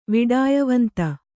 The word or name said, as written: ವಿಡಾಯವಂತ